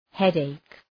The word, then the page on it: headache: ‘hed,eık